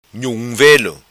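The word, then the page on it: Velo Nhum: patua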